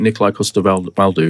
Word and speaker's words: Nikolai Coster-Waldau: I think Nikolai Coster-Waldau is right, yeah. – Yeah. – Yeah. – It is, because I watched him say it.